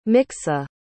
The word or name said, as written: miksa